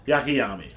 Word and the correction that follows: Akiyama: Akiyama. – Akiyama. Why are you telling me to say it when you just said it? – Because you mispronounced it, so I was correcting you.